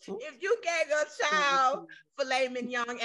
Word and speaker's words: Filet Mignon: What you call Mignon. It? Filet Mignon. Filet Mignon.